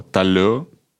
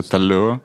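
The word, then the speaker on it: talent: Там есть такое слово «талё», ну, оно пишется как «талент», да, но ну, читается «талё».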